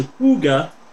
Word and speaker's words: hygge: In 2016, Finland gave us the word H-Y-G-G-E, which is pronounced hygge, apparently. H-Y-G-G-E.